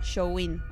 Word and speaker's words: Samhain: Y, se llama Samhain o se pronuncia Showin, algo así.